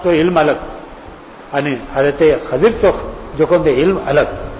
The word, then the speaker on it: خضر: خضر لفظ صحیح لفظ خضر مانتے عوام جو کہتے ہیں خضر خضر خضر بلتا لیکن خضر تو صحیح تلفظ خضر